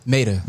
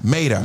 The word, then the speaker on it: Maida: You got, is it Maida? – Maida. – Maida. – Yep. – Maida, who is bonkers. – If y'all know about Maida, y'all better go get yourself familiar. – Rhymes with tomato.